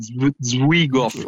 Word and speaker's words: Zwigoff: vado a sbagliare la pronuncia, da Terry Zwigoff, credo si dica così. È giusto.